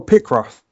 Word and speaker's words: Picross: Oh, Picross.